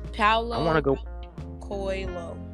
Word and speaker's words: Paulo Coelho: Paulo Coelho. He's Brazilian. – Paulo Coelho.